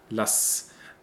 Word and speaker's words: lass: But here it is written with an SZ too, just like in Lass, which is also a short A sound. Lass.